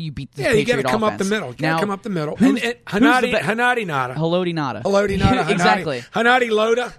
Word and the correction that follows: Haloti Ngata: Nose tackle in the game is, but since you can't pronounce his name, his name is Haloti Ngata.